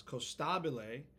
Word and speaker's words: Costabile: See, this is an Italian thing I'm getting really stubborn about. It's Costabile. – Costabile. – But growing up, no one said it that way. But now that I'm on stage and I want strangers to say it, I'm making them say it Italian.